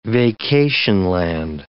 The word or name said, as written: vacationland